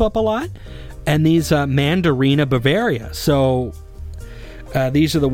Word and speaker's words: Mandarina: Mandarina is the correct way to say it.